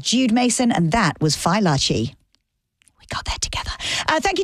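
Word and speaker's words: Fai Laci: So it's spelled F-A-I and then next word, L-A-C-I. So it's Fi La Chi. They're from Boston. Fi, F-Y-E, L-A-H-C-H-E-E. Because I've been saying fallacy or filacy, but no. So Larchi, Fi Larchi, rhymes with botchy, Larchi. So Fi Larchi.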